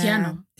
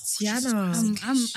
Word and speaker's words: Tiana: But she was approached to play Miss Tina, Princess Tina, sorry. I hope I'm saying that right. From Disney. – Oh, yeah. Tiana. – Tiana.